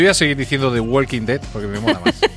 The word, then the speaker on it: Walking Dead: Walking Dead.